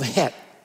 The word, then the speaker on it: Heth: So we are going to look today at the Hebrew letter that's called Het. It's spelled H-E-T-H. It's not Heth. So L, if you're here or you're listening, don't think your name got in the Bible there, okay? It's Het.